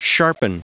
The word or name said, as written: sharpen